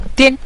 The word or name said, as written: dian5